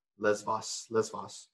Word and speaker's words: Lesbos: We were just talking about humanitarian work with refugees in how do you say it? Lebos? Is that right? – Lesbos.